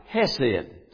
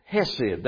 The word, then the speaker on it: hesed: And the word mercies, the Lord's mercies in Hebrew, is the word pronounced hesed. Hesed. That's the way that word mercies is pronounced, hesed. And it's spelled C-H-E-C-E-D. Now, I don't think you'll get that pronunciation out of it when you look at it.